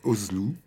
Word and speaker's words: Özlü: Tezer, hein, ça s'écrit. Tezer. Je le dis parce que voilà. Quand on prononce le nom, on ne distingue pas forcément. Tezer, T-E-Z-E-R, Özlü, O, tréma, Z, L, U, tréma.